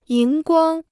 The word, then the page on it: 荧光: yíng guāng